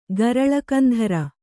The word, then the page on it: ಗರಲಕಂಧರ: garala kandhara